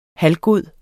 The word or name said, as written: halvgod